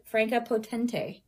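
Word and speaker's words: Franka Potente: Played by Franca, and I looked up how to say this because I've been saying it wrong my whole life. Franca Potente.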